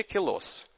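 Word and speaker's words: Queloz: Метод эффект Доплера, открыватели Мишель Майор и Дидье Келос. Я, кстати, лично спрашивал Мишеля Майора, как правильно произносить товарища Квелоса, как я сказал. Он сказал, Келос, он француз.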